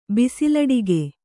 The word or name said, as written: ಬಿಸಲಡಿಗೆ